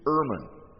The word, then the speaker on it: Ehrman: And the way it's written out in the accusative case is Erman, as in E-R, let's see, E-H-R-M-A-N. Do you know who Erman is? Who recognizes that name? Just one or two of you. You need to know the name Bart Erman. Sometimes it's pronounced Ehrman.